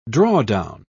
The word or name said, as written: drawdown